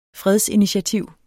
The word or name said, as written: fredsinitiativ